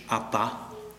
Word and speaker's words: Abba: Und diese neue Anrede heißt aramäisch Abba. Ton liegt auf der zweiten Silbe.